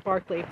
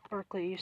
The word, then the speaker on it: Berkeley: I'm an award-winning Clark Barkley. That's the British way to pronounce his name. Berkeley, UC Berkeley, is named after the philosopher.